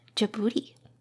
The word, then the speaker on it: Djibouti: Djibouti. Djibouti? The D is silent. Consists of three syllables, like jug without the G, stress the boo, and the T. Like booty.